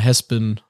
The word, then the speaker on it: Hazbin: Haspin mit H-A-Z-B-I-N, richtig? – Ja, genau. Aber ausgesprochen Haspin aus offensichtlichen Gründen.